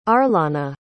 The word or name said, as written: arlanna